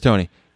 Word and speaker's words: Toney: Their first-round selection, the wide receiver from Florida, Tony is how you say it. – Kadarius Toney.